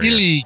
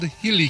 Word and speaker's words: Healy: Healy Carrier, yes. Because it rhymes with shield, Healy Carrier.